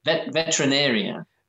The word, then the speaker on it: veterinarian: but veterinarian. We call the veterinarian. – Oh, you did fine. Veterinarian. Some people add the extra syllable in there and say veterinarian or veterinarian.